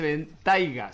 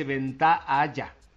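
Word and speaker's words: Bentayga: Porque no se dice Ventaiga, se dice Ventaaya.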